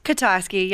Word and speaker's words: Kutosky: Kutosky, yep. Kutosky. Close enough. All right. It's Polish.